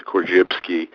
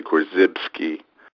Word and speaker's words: Korzybski: I give it the Polish pronunciation, Korzybski, but many people just say Korzybski.